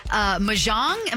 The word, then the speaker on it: Mahjong: Mahjong, am I pronouncing that right? – Yep. – Mahjong? – Mahjong, I think.